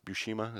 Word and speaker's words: Buscema: And the Superman one was with John Buscema. Is that how you say his name? – Yes.